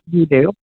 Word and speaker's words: dudou: It's spelled D-U-D-O-U. Doo-doo. – So it is doo-doo. All right, doo-doo. – It's pronounced doo-doo.